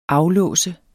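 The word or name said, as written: aflåse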